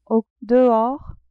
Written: au dehors

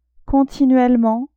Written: continuellement

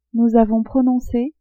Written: nous avons prononce

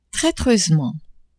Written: traitreusement

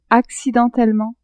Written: accidentellement

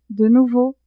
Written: de nouveau

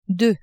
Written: deux

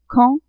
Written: quand

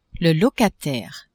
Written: locataire

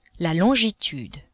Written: longitude

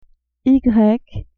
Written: y

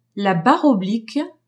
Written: barre oblique

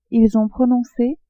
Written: ils ont prononce

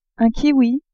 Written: kiwi

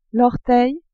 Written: orteil

